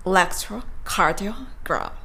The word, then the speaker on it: electrocardiogram: electrocardiogram。